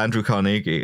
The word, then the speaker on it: Carnegie: Although, the one thing I remember about Andrew Carnegie